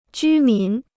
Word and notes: 居民: jū mín

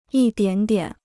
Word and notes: 一点点: yī diǎn diǎn

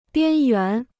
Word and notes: 边缘: biān yuán

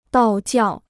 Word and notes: 道教: dào jiào

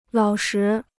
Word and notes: 老实: lǎo shi

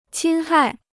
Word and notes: 侵害: qīn hài

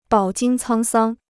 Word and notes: 饱经沧桑: bǎo jīng cāng sāng

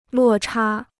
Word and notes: 落差: luò chā

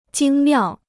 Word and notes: 精妙: jīng miào

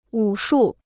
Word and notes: 武术: wǔ shù

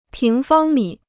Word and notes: 平方米: píng fāng mǐ

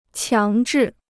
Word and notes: 强制: qiáng zhì

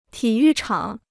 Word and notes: 体育场: tǐ yù chǎng

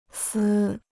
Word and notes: 丝: sī

